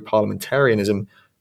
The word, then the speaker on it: parliamentarianism: the crisis of contemporary parliamentarianism, is that how you say that? The crisis of contemporary parliamentarism, how do you say that word? – Parliamentarianism. – Ah, okay. The crisis of contemporary parliamentarianism would not be overcome in the least.